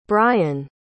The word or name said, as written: bryon